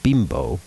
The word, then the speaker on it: Bimbo: The preposterously named Bimbo Bakeries USA, pronounced Bimbo, but still.